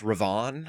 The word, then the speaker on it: Raven: It's actually pronounced Ravon.